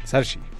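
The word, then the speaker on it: Saoirse: Eh, René Selweger, Judy, Charlize Theron por Bombshell, eh, Cynthia Erivo por Harriet, Scarlett Johansson por Historia de un matrimonio y Sarah Ronan, siempre lo pronuncio mal. Sarshi.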